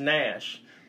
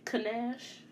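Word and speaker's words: Knash: Kanash. – No, Kanash. No, that's the, a dessert, ain't it? No, the K the K is silent. So why is it there? Because nobody spells Nash that way.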